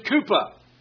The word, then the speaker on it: Kuppah: And this fund was called, I think you pronounce it, Kupa. K-U-P-P-A-H.